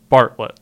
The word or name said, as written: Bartlett